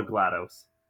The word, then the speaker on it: GLaDOS: I don't know if it's pronounced GLaDOS or GLaDOS, because it's like DOS, like DOS, but it looks like GLaDOS. I really don't know how you say it. – They call her GLaDOS. – Yeah, I'm going GLaDOS as well.